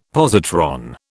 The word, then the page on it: positron: British English